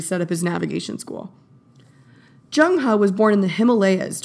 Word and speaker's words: Zheng He: By the way, it's spelled Z-H-E-N-G-H-E. It looks like Zheng He, but I promise it's Zheng He.